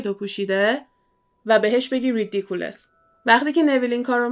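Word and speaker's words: Riddikulus: این وردشه که مدل اسپلش فرق میکنه و تلفظش ریدیکولس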